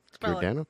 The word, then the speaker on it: Giordano: Giriondano? – G-I-O-R-D-A-N-O. – I would say it was a G sound. – Yeah. – Because, you know, Gerardo and stuff like that, Spanish names had the hard G.